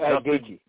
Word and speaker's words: Gaethje: But you got, how do you pronounce the last name? Is it Gaethje? Is it Gaethje? – Yeah, okay.